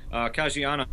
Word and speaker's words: Caggiano: Caggiano, and he's actually in Volbeat now.